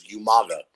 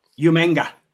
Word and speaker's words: Umaga: And then you had William Regal, who could never pronounce his name correctly. He was Umaga. – Umanga. – Umanga, yeah.